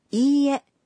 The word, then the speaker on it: いいえ: いいえ means no. When you say いいえ, you pronounce い 1 beat longer.